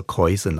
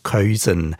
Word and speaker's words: Keusen: Wir haben vorgängig miteinander telefoniert und er hat mir gesagt, man spreche den Namen so aus, wie man ihn auch schreibt, also Keusen, also Keusen, so ein bisschen berndeutsch ausgesprochen.